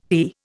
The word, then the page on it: fee: Indian